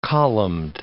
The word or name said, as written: columned